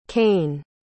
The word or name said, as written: cain